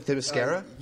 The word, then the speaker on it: Themyscira: I am your co-host, and joining me, the first man to step foot on Themascalis. Did I pronounce it right this time? Themyscira? – You were very close.